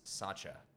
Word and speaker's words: Sacca: It's going to be Ottara, O-T-T-A-R-A. Middle name is Sacha, S-A-C-C-A. Last name is Pyne, P-Y-N-E.